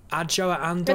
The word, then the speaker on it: Adjoa Andoh: It's Adjoa Andoh.